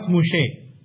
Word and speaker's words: Moshe: That's why the Jews call it Torah Moshe. That's how they pronounce it.